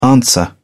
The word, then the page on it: answer: British English